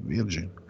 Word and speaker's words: Virgin: Nel focolaio emerso all'interno della palestra Virgin di Città Studi, trovata, spero che la pronuncia sia giusta, Virgin o Virgin? Virgin. Like a Virgin o Like a Virgin, cantava Madonna.